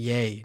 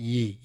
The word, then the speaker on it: Ye: But oh, it's pronounced like yay, not ye.